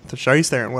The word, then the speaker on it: Charlize Theron: Charlize Theron. – Charlize Theron, whatever.